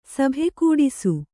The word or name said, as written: ಸಭೆಕೂಡಿಸು